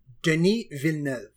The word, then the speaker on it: Villeneuve: Can you pronounce it one more time for me? Denis Villeneuve.